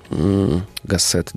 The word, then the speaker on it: Гассет: Гассет, да, я туда-сюда прыгаю, ударение.